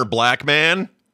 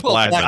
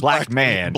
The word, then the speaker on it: Blackman: I didn't know Pussy Galore's real name was Honor Black Man. – Yeah. – Black Man. – I don't think it's Black Man. – Blackman. – I think it's Blackman. Yeah.